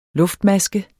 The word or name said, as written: luftmaske